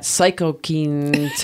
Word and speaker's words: cytokine: It's that cytokines.